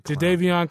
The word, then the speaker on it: Jadeveon: Is that how you say his name? – Jadeveon Clowney.